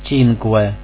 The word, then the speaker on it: Cinque: Также буква «U», как английская «W», произносится в данных словах. «Cinque» – «пять».